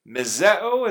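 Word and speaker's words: Mizeo (μισέω): Mizeo is how it's pronounced.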